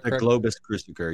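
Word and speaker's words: Globus cruciger: The Krobus Krusiger. Is that how you say it? – Correct. Globus Krusiger.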